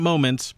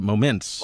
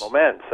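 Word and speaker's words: Momence: Did I do the emphasis on the right syllable? – You sure did. – Not moments, but moments. – Moments, that's right.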